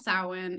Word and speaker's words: Samhain: So I already said it's pronounced Samhain and it's Irish Gaelic.